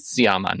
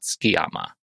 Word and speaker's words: Sciamma: So she's it's got to be Siama, not Skiama.